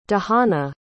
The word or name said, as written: dahana